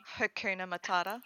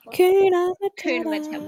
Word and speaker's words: Hakuna Matata: Oh, but I do like the Kuma Tata. You know, Hakuna Matata. – Hakuna Matata.